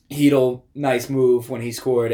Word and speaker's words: Chytil: Chytil? – I wanted to mess it up, but okay, we'll say Chytil.